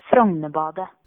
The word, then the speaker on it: Frognerbadet: Jag har övat så mycket att jag bad min norska väninna berätta för mig hur man ska uttala det. – Frognerbadet.